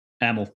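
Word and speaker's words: Amyl: Is it A Mill or Amel? – Amel, yeah. Amel and the Sniffers.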